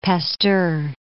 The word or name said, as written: Pasteur